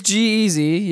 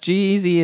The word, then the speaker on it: G-Eazy: I don't even know how to pronounce this girl's goddamn name because it is... – I see G-Eazy in the... Okay, it is G-Eazy. Yes, G-Eazy is.